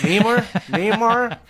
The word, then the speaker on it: Namor: How do you pronounce his name? Namor? – Yes, that one.